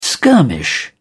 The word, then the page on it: skirmish: British English